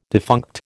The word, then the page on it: defunct: American English